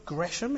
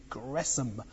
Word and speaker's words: Gresham: That is the received pronunciation, just in case you were wondering. It's not Gresham, it is Gresham.